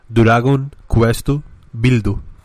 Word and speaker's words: Dragon Quest Builders: En, japonés se pronuncia Dragon Quest Buildu.